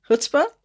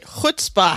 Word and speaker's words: chutzpah: Chutzpah? Did I say it right? – We'll take it. Chutzpah. – Chutzpah. – You need that, like you're clearing the back of your throat.